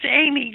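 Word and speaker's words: Eighmey: Egg me? – You won't. – Close. It's Amy, just like the girl's name.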